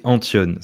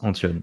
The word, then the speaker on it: Antton: C'est Antione. Ça se oh. prononce Antione. Ça s'écrit euh, bizarrement, mais ça se prononce Antione.